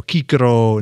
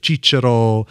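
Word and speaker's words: Cicero: Every C is a hard C. Kaiser, you know, Kikero instead of Chichero.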